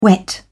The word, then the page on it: whet: British English